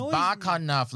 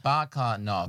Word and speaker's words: Bakanov: Dude, it's Bakanov. Like baklava in November. – I didn't understand any of that explanation, but fair enough. – No, Bakanov.